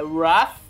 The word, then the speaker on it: Wrath: Não é of a Man, é Wrath. – Wrath. – É Wrath of Man. – Ah, obrigado. Eu sei que foi alfabetizado em Brighton, corrigindo aqui a minha pronúncia, muito bem.